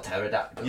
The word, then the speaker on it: pterodactyl: Is that how you spell pterodactyl? – Yeah, it is, yeah. – With a silent P? – Yeah.